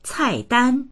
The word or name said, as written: cai4dan1